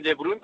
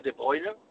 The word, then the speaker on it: De Bruyne: porque muchos dicen De Bruyne, pero se, se, pronuncia De Bruyne, y a Gundogan es, esos, son, esos dos jugadores hay que, como suele decir, apagarlos.